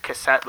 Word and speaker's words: cassette: Because in French, 7 is set, and K7 is cassette. – Oh. Oh, so is it actually pronounced cassette?